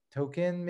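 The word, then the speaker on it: Tokken: I think Token maybe is how you pronounce it.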